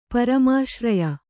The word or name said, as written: ಪರಮಾಶ್ರಯ